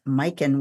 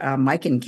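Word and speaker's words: Myken: But so some of the other distilleries are, I mentioned Det Norske Brenneri, Mikkel Distillery, and that's spelled D-I-S-T-I-L-L-E-R-I, and it's Mikkel with a Y, M-Y-K-E-N.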